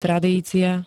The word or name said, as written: tradícia